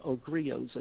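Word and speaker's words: griot: We have to make sure those of us who are the griots, those of griots, as I think is correct pronunciation,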